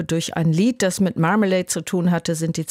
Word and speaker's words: Marmalade: Marmalade waren das, so würde ich es erstmal aussprechen, habe aber jetzt gesehen, es wird Marmalade ausgesprochen, wie das englische Wort für Marmelade, also die Orangenmarmelade, aber in zwei Worten geschrieben, Mar, also wie das Meer eigentlich auch, und Malade, Sie kennen vielleicht auch den Ausdruck, ich fühle mich heute ein bisschen Malade.